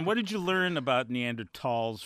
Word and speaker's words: Neanderthals: Neanderthals.